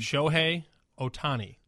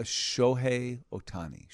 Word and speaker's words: Shohei Ohtani: Shohei Ohtani. – All right, everybody, Shohei Ohtani. I've seen it, and I know he's a thing, but I wasn't... S-H-O-H-E-I, Shohei. – Shohei Ohtani. – O-T-A-N-I.